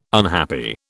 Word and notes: unhappy: British English

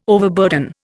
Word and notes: overburden: Indian

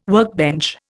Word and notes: workbench: Indian

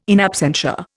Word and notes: in absentia: Indian

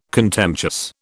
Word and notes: contemptuous: British English

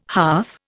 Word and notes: hearth: Indian

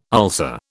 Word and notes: ulcer: British English

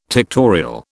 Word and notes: tectorial: British English

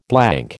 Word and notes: blank: American English

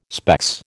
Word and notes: specs: American English

